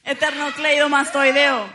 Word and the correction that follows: esternocleidomastoideo: Esternocleidomastoideo, exacto. ¡Eso! Lo dije bien.